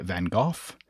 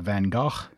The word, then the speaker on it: Van Gogh: So Americans say Vincent van Gogh. Brits say Van Gogh. Neither of those really makes any sense from an English language pronunciation standpoint, but whatever. And then Dutch people say something like Van Gogh, and I'm probably butchering that.